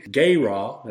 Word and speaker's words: gerah: After the shekel of the sanctuary, a shekel is 20 gerah, that's how that's pronounced, and half a shekel shall be the offering of the Lord.